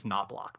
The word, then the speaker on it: Knoblock: And I have an added wrinkle because my last name is Knoblock. Now it's spelled K-N-O-B-L-O-C-K.